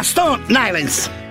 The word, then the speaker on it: Stone Island: Che gym, che c'è la Questi gym della Stone Islands. – Stone Islands. – Ma perché scrivono? Scrivono come si legge. – Si chiama così, Stone Island.